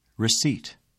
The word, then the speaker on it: receipt: The letter P is silent in the following words. Repeat them during the pauses. – Receipt.